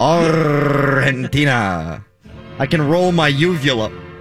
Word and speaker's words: Argentina: Is it a soft G with Argentina? – Yes, it's almost like the G isn't even there. Argentina. Oh. – I think you got to roll the R too.